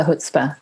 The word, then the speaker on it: chutzpah: You need a chutzpah. – You need the chutzpah. Now I know how to say it properly.